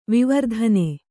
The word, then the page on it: ವಿವರ್ಧನ: kannada